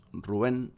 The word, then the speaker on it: Rubén: Y quien colabora en esta canción es nada más y nada menos que Rubén Blades. O Rubén Blades, para el resto de los hispanohablantes. Nosotros en Uruguay tenemos la costumbre de a los Rubén decirle Rubén, como acentuando esa primera U.